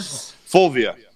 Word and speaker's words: Fulvia: Fulvia.